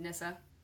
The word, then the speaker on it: Nisse: But there are these little Scandinavian elves called Nissa, or like little Nissa men, and it's spelled N-I-S-S-E. – But pronounced like Nissa.